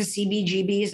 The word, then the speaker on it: CBGBs: You went to CBGBs. Am I saying it right? – Yeah, you are.